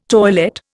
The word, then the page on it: toilette: Indian